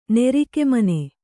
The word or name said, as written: ನೆರಿಕೆಮನೆ